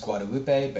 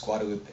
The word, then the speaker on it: Guadalupe: This was at Jade Leaves Tea House, which was on Guadalupe, which I know is supposed to be pronounced Guadalupe, but here in Austin it's pronounced Guadalupe.